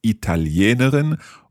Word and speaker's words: Italiener: Ich bin Italiener. – Italiener. With an R at the end? – Genau.